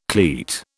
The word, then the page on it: cleat: British English